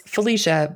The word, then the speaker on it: Phylicia: Yeah, so it's Phylicia,